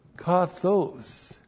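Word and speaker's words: kathos (καθώς): And then the adverb kathos. K-A-T-H long O-S. And kathos means as or to the degree that.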